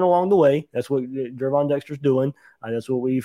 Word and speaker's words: Gervon: So Gervon Dexter, gotta say his name right, not Gervin, Gervon.